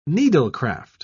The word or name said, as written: needlecraft